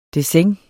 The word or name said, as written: dessin